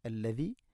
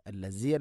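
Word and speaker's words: അല്ലദീ (الذي): ഹുവല്ലാഹുല്ലദീ ഹുവല്ലാഹുല്ലദീ അല്ലദീ, ദാലിൻ്റെ ഉച്ചാരണം ശ്രദ്ധിക്കുക. അല്ലസി അല്ല അല്ലദീ.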